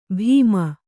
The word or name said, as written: ಭೀಮ